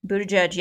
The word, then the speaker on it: Buttigieg: So our last quick story, this is around Pete Buttigieg. Did I say it right? – Buttigieg.